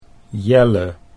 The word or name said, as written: Jelle